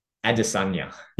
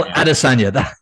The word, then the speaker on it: Adesanya: Israel Della Adesanya. – Yeah. Israel Adesanya.